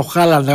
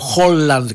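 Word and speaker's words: Haaland: Στο 44 ο Χάλαντ, ο Χόλαντ κατά τους κατά άλλους. Σήμερα την προφορά έχασε γκολ.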